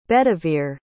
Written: bedivere

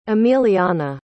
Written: emiliana

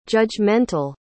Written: judgemental

